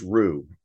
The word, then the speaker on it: Roux: In addition, we also license Roux. It's spelled R-O-U-X, and that's our core.